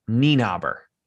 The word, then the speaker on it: Nienaber: It's Nienaber.